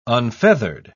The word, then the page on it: unfeathered: British English